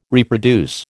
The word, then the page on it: reproduce: American English